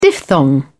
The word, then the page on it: diphthong: British English